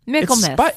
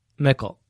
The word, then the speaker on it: Michaelmas: Saying it right, Michaelmas. – Michaelmas. – It's spelled Michaelmas. – Yes, and we know that in the English language, everything is pronounced how it looks.